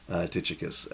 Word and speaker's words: Tychicus: Mostly because when I studied Greek a very long time ago, I pronounced names, words, I pronounced everything so that I would remember how to spell it. Spell the word on an exam. So I would not have said Tychicus. But that is likely the best way to say it.